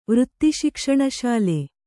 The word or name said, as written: ವೃತ್ತಿಶಿಕ್ಷಣಶಾಲೆ